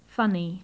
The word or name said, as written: funny